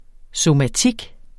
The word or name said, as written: somatik